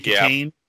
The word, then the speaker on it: Kitaen: Is it Kitaen? Tawny Kitaen.